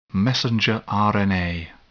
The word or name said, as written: messenger RNA